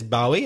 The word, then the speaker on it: Bowie: In fact, he was such a changeable character that there are even two ways to pronounce his surname. Craig says Bowie, and many people do, and I say Bowie. You'll hear both.